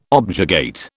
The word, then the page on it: objurgate: British English